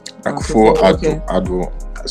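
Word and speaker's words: Akufo-Addo: Akufo Addo.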